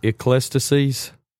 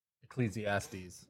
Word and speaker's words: Ecclesiastes: Ecclesiastes. – Ecclesiastes.